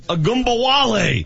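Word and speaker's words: Ogunbowale: That's how you pronounce it, Ogumba Wale.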